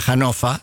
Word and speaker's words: Hannover: Había un restaurante en se escribe Hannover, se pronuncia Hannover en alemán.